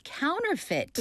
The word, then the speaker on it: counterfeit: C-O-U-N-T-E-R, counter, mm-hmm. 그리고 그 뒤에 F-E-I-T. 발음 다시 한번 확인해 줄래요? – Counterfeit. Counterfeit. 자, 그냥 fit 같지만 이게 여기서 e가 하나 숨어 들어가 있어요.